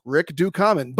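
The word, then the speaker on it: Rick Ducommun: The man's name is pronounced Rick Dukaman,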